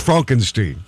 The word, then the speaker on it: Frankenstein: According to Entertainment Weekly, his most essential film is The Producers, followed by Blazing Saddles and Young Frankenstein. Oh, wait, I'm sorry, that's pronounced Frankenstein.